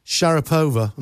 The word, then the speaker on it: Sharapova: That's why I wanted to talk to you about Maria Sharapova. Am I pronouncing that correctly? – Yeah, you got it right.